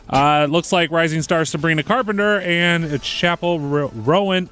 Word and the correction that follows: Roan: I always say her name wrong, Roan, they both secured wins, while Taylor Swift and Billie Eilish were notably absent from the winners list.